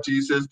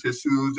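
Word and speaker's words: Jesús: So one of the nurses pulled me back and they said, you know, this is not Jesus, this is Jesus, this is how you pronounce it.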